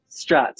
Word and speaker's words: Strat: Is it Strat? – Strat, yeah. Strat. – Is it meant to sound like bat? – It's named after Stratocaster, which is a type of guitar. So yeah, I think it's just a coincidence that it rhymes Strat and bat, yeah.